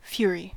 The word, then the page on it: fury: American English